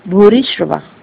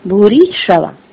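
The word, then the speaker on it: भूरिश्रवा: अक्सर है ना बहुत सारे लोग आप सब में जब बताया है श्लोक तो भूरीश्रवा शब्द सही नहीं उच्चारण किया है, ये ऐसे है भूरीश्रवा, कैसे, सोमदत्त के पुत्र भूरीश्रवा, सही है भूरीश्रवा।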